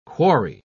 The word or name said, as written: quarry